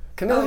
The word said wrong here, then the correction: Kamala: Kamala. – Sorry, yes, I mispronounced it. Sorry. – Or Kamala, actually. – Kamala. That's like Caribbean or caramel.